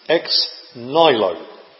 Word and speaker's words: ex nihilo: It's a Latin phrase, and you can either pronounce it the proper way, or the way that will help you to remember how to spell it. the proper way is ex nihilo.